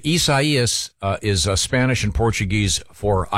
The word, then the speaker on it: Isaias: It's Hurricane Isaias. Isaias.